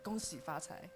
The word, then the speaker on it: Gong xi fa cai: That's Cantonese. I speak Mandarin. – Oh, okay. – But I can speak Mandarin version of it. Gong xi fa cai.